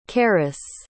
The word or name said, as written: Cerys